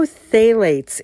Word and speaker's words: phthalates: is there's no phthalates in it. Now, I know I've said something about phthalates before. Phthalates, it's a big, long word with only two syllables. P-H-T-H-A-L-A-T-E-S.